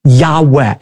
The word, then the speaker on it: Yahweh: What we actually have there in the original manuscripts are the letters Yud, Hey, Vav, Hey, which are God's personal name that most Semitic scholars believe is pronounced a breathy Yahweh.